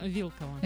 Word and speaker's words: Вилкова: Я просто не произношу фамилию, потому что я не знаю, как правильно ударение. Вилкова или Вилкова? – Вилкова.